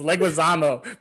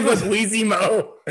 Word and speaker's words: Leguizamo: It's Leguizamo, but you said Leguizimo.